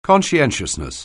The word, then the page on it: conscientiousness: anglais